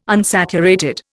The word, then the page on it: unsaturated: Indian